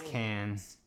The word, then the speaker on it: Cannes: I'm pretty sure that festival is pronounced Cannes. – Yeah, it's Cannes.